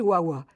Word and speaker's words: VAVA: Et au-dessus, on a une inscription V-A-V-A. Or, le V se prononce W, ce qui fait Wawa.